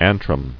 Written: Antral

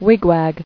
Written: Wigwag

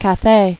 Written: Cathay

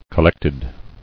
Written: Collectedness

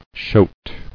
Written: Shote

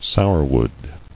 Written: Sourwood

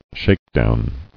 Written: Shakedown

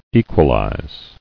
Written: Equalized

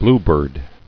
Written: Bluebird

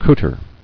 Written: Cooter

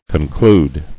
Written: Concluder

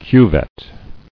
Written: Cuvette

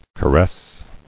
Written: Caressingly

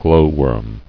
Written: Glowworm

